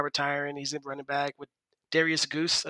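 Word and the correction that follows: Guice: I believe, backing him up. Geis, sorry, butchered his name.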